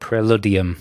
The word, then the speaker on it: Preludium: And you can get all of that by listening to the album Predalium. Oh, I think I'm pronouncing that? – Preludium. – Preludium. – It's definitely a U in the middle there.